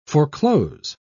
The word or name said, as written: foreclose